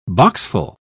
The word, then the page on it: boxful: British English